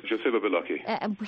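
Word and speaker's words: Joseba Beloki: There's Spain's, am I saying this right, Joseba Balaki? – Yes, Joseba Balaki.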